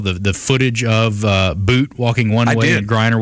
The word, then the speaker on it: Bout: Now, all day I was saying Victor Bout. Apparently it was the Canadian pronunciation is the correct way to go about that. So Victor Bout is the name of the arms dealer.